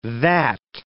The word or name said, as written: that